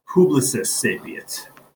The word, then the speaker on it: Publicis Sapient: So Publicis Sapient. That's how you pronounce it.